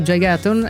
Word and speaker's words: Gigaton: Per la Hit Parade di questa settimana, Gigaton è l'11° album, no, Gigaton dice, sì, Gigaton, è così si pronuncia,